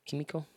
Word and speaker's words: Kimiko: Is it Kimiko? Kimiko? – Yeah, that's it.